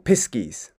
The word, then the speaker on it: piskies: So piskies are just a pixie, just a different sort of pronunciation.